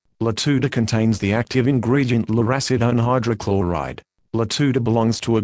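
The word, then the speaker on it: lurasidone: Luracidone hydrochloride, pronounced Luracidone hydrochloride.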